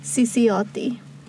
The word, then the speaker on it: Cicciotti: I finally learned how to say their last name, Cicciotti. They're Italian, so I was like, ooh, okay.